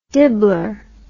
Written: dibbler

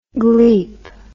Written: gleep

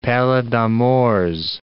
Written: pallida Mors

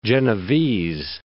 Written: Genovese

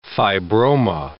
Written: fibroma

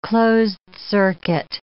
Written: closed-circuit

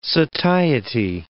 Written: satiety

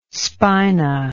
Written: spina

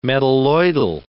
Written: metalloidal

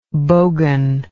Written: bogan

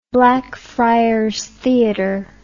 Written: Blackfriars Theater

